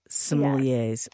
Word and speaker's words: sommeliers: I was confused about how to pronounce sommelier in plural. – Sommeliers.